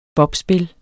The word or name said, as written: bobspil